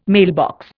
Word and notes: mailbox: Indian

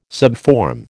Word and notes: subform: American English

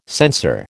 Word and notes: censor: American English

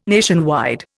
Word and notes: nationwide: Indian

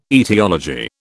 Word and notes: aetiology: British English

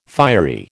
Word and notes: fiery: American English

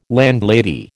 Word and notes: landlady: American English

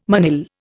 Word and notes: manille: Indian

